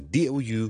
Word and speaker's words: doubt: 最常见到我哋成日都讲 silent letter 嘅例子咧，就系我哋怀疑 doubt 好怀疑一件事，中间个 b 咧，我哋系唔需要读出嚟，所以我哋唔读成 doubt， but doubt，当系好似 out 个 out 啦，加个 d 喺前面就系个 doubt。